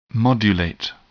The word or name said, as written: modulate